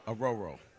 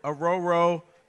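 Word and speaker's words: Orhorhoro: Aroro. That's how you pronounce it, Aroro. – Sounds great. – Rook Aroro, and he's actually from here.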